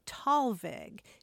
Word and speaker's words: Thalweg: It's usually pronounced Thalweg, and it is a combination of two German words.